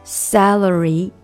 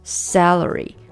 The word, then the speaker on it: celery: celery 芹菜，注意这个词呢，celery 它跟薪水 salary 非常的接近，一个是 celery，e 的发音 s celery，另外一个是 salary salary，这个是 a 的短音，就是那个梅花音 short a sound salary celery salary 两个是不一样的啊。